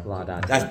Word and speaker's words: Laudate: Laudate. – That is the correct pronunciation. – Okay. – Yeah, Laudate. I was messing it up.